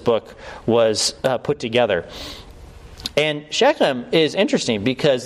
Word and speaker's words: Shechem: Okay, Shechem. Technically, in Hebrew, you're supposed to pronounce that as Shechem.